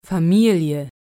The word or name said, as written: Familie